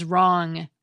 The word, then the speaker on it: wrong: Another long-lost cluster is WR, which has given way to the silent W in words such as wrong, wreath, and wrestle.